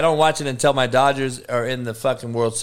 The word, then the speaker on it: Dodgers: My Dodgers, the Doyers, how we pronounce it here in L.A.